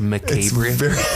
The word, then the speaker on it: macabre: I believe it's pronounced macabre. – Macabre. – Is it macabre? – It is. Okay. You're correct.